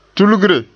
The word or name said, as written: tulgre